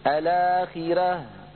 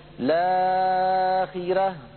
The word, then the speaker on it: الآخرة: فالبدل قد تغير، فإن اعتددنا بالأصل ومددنا هذا البدل المغير بالقصر والتوسط والطول، لابد لنا أن نعتد بالأصل أيضا فننطق همزة الوصل، فنقول الآخرة، هذا على وجه القصر، الآخرة، هذا على وجه التوسط، الآخرة، هذا على وجه الطول. ولا يصح أن نقول لآخرة، لا أربع حركات، ولا لآخرة ست حركات.